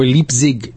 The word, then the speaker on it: Leipzig: We're not in Leipzig, Germany, or Leipzig, Germany, I should pronounce it properly as best I can.